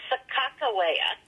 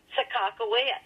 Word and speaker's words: Sacajawea: Another pronunciation is Sacajawea, which is the second most accepted pronunciation. And if you're from North Dakota, you would say Sacajawea.